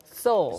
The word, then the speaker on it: Seoul: 영혼하고 똑같은 발음이죠. – 영혼, right? Seoul. – Seoul, S-O-U-L, 영어로 이제 영혼이지만 또 서울이라는 지명이 영어로는 또 Seoul처럼 발음이 됩니다.